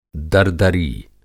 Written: दरदरी

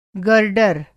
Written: गर्डर

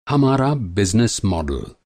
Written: मॉडल

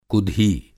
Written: कुधी

